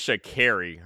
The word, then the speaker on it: Sha'Carri: By the way, it's Sha'Carri. Her name is actually Sha'Carri. – My bad. – You've been saying it wrong the whole time.